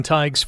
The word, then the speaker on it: Tadhg: And for anyone who hasn't seen the spelling on Tighe's first name, T-A-D-H-G, pronounced Tighe.